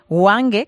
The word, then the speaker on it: Hwange: Bueno, ahora mismo estás destinado en la diócesis de Hwange, creo que se pronuncia así, ¿verdad? – Sí, sí.